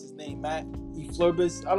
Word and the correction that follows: Eberflus: Oh, Eberflus.